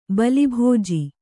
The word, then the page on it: ಬಿಲ್ಬಿಜ್ಜೆ: bilbijje